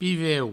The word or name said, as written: piveu